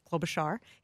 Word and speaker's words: Klobuchar: Amy Klobuchar, and he said he got a deep dive immersion lesson on how to pronounce her name, Klobuchar.